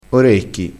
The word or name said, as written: orecchi